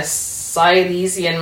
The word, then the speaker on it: Silesian: Tuberculosis, hallucinogenic fungal liquor, sinister local folklore in the Silesian Mountains as the First World War looms, strange and funny and incredibly compelling. I hope I pronounced that mountain range correctly. – Yeah, Silesian, yeah.